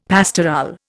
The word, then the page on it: pastorale: Indian